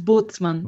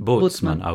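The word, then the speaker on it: Bootsman: Because it's spelt Boot, B-O-O-T, in the English pronunciation, but I think it's Boatsman. Have I got that right? – It's Boatsman. – Boatsman.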